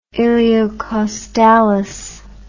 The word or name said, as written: iliocostalis